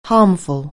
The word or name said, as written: harmful